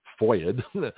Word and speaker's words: FOIA: He says, Gutzkow, G-U-E-T-Z-K-O-W. Doesn't sound very Jewish, but that's okay. Gutzkow, who has secured numerous FOIA'd documents, that's F-O-I-A, they call it a FOIA, which is a pronunciation of Freedom of Information Act, F-O-I-A acronym.